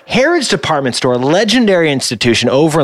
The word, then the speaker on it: Harrods: In Trafalgar Square, you got Harrods.